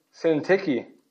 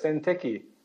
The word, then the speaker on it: Syntyche: And I did look that up this morning, how to pronounce. I used to say Syntyche or Syntyc, but Syntyche, that's what it is. Sounds kind of funny, but that's it. That's the official pronunciation.